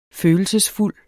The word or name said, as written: følelsesfuld